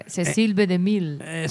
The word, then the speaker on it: DeMille: Por favor, esa pronunciación en francés, hombre. Cecil B. DeMille.